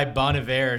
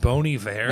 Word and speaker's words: Bon Iver: Bugged by Bon Iver or something. – Actually, it's pronounced Bon Iver.